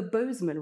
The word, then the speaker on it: Bosman: The Bosman ruling. – Go on. – Did I do it? – Nailed it. – The Bosman ruling. – Yeah. Bosman or Bosman? – Bosman.